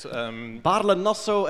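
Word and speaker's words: Baarle-Nassau: Barle Nosso and Barle Hertog, yes.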